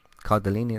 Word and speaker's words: Cardellini: Uh, Marvelous Mrs. Maisel, Linda Cardelli, Car- Cardellini. Cardellini, that's it.